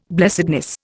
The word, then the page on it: blessedness: Indian